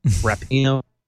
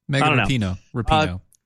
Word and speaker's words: Rapinoe: Is that how you say your name? Rapinoe. – Megan Rapinoe. Yeah, you got it right on the second one.